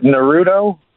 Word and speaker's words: Naruto: Is it Naruto? – Okay.